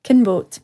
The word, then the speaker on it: Kinbote: It sounds like, no offence to the actual pronunciation, Kinboat, but Kinbote.